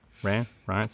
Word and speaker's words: Reims: Reims.